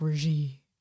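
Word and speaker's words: Regé-Jean Page: Now, I'm going to pronounce this name wrong, but Reggie Jean Page of Bridgerton, that's not how you say it. I'm pretty sure it's like Reggie.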